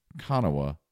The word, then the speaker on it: Kanawha: Kanawha Falls, spelled K-A-N-A-W-H-A, looks like Kanawha, pronounced Kanawha.